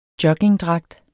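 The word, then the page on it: JOGGINGDRAGT: Danish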